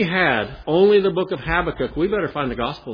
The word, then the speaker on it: Habakkuk: Now would you turn with me to the book of, I hope I'm pronouncing it correctly. It may be correct in Oregon, but it may not be correct in Michigan. Habakkuk. Or Habakkuk, however you want to pronounce it.